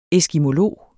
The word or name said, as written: eskimolog